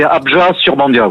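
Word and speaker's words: Abjat-sur-Bandiat: Alors, oui, on prononce pas forcément le T final, c'est Abja-Surbandia, oui.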